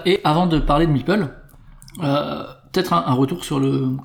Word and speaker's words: Meeple: Une espèce de figurine, c'est le Meeple. Le Meeple, qu'on prononce M-I-P-E-U-L, hein, comme c'est précisé, je crois, dans Carcassonne, mais qui s'écrit M-E-P-L-E.